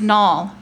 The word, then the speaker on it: Nall: For the record, my name is spelled D-E-A-N-A, and it is pronounced Dina. It's Dean with an And my last name is Nall, like Mall with an N.